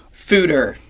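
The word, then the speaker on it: foeder: Is that how you say it? Food is it? – Fooder. – Fooder. Okay.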